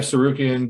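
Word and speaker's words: Tsarukyan: Sarukian. – The T is silent.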